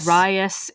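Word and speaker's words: Ryace: There's R-Y-A-C-E. – Yeah. – There was a note of how to pronounce it. – You know, that's a microaggression to mispronounce that person's name. – Oh, I found it. – We're going to have a... – Rias is the name.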